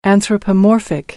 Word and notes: anthropomorphic: American English

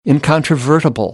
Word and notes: incontrovertible: American English; ɑː

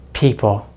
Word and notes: people: American English

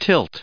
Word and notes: tilt: verb

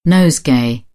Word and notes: nosegay: British English; əʊ

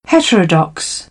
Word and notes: heterodox: British English; əɹ

